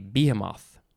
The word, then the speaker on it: behemoth: But now maybe I'll go back to saying a behemoth. It's just behemoth. There's no H in that other pronunciation.